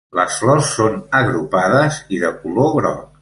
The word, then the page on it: agrupades: Catalan